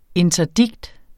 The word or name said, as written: INTERDIKTERNES